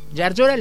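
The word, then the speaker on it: Llaryora: con doble L comienza el apellido, Llargiora. Es difícil de pronunciar, pero sí, Llargiora le dice todo el mundo, ya como la Y de Gillo al comienzo, es doble L.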